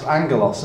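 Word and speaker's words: angelos (ἄγγελος): And it looks like agelos, but it's actually pronounced angelos. And that's actually where we get our English word angel from, A-N-G-E-L, because that actually is pronounced angelos, and it literally means messenger.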